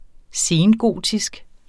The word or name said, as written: sengotisk